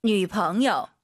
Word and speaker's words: 女朋友: 女朋友. – 女朋友. 남자친구와 발음이 달라요. 성조가. 남朋友. 女朋友.